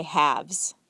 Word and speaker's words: halves: And so for both forms, singular and plural, the L is silent. But notice also there's a consonant change. In the singular, there's an F, half. And in the plural, it changes to a V, have, halves.